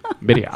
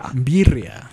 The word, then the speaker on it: birria: Birria. – Birria.